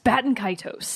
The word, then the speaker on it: Baten Kaitos: It's B-A-T-E-N and then K-A-I-T-O-S. Baten Kaitos.